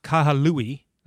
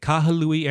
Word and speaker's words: Kahului: So we departed from Maui Airport, Kahului, that's it, that's how you say it, Kahului Airport on the island of Maui.